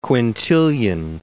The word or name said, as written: Quintilian